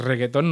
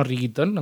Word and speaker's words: reggaetón: Luego el reggaetón, sí es reggaetón, no riguitón, aunque se escribe igual.